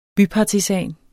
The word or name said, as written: bypartisan